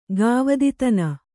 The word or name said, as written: ಗಾವದಿತನ